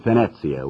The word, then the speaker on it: Venezia: Those are just interesting things that don't prove anything one way or the other, and frankly, I don't think the city of Venezia, which is the way they pronounce it over there in Italy, or Venice, as we say in English,